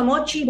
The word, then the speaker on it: Mocchi: La verdad es que hermoso, hermoso Mochi, búsquenlo. Mochi se escribe con doble C, ¿sí? M-O-C-C-H-I.